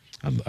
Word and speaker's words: Etienne: Etienne.